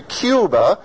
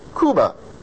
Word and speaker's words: Cuba: So they don't pronounce the name of their country, Cuba. They pronounce it Cuba. Person is from Cuba. We say it Cuba, or the C-U-B-E, the like a Rubik's Cube.